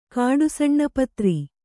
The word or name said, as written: ಕಾಡುಸಣ್ಣಪತ್ರಿ